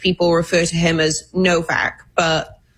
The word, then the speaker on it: Nowak: But it's the case of, I've been saying, Henry Nowak. Kay, of course, is from the UK and says that it's really not. It's pronounced Novak. A W is pronounced V.